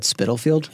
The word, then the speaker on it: Spitalfield: At Spitalfield? – Yeah. – Yeah. – You got to have the spit in it.